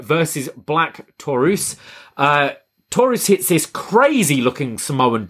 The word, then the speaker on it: Black Taurus: Have I said that right? I thought it was just Black Taurus, but yeah, Black Taurus. – Yeah, you got it right. – I got it right. Awesome.